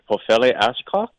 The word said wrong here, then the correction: Ashlock: It's Ashlock. – Oh, geez. – It's an L.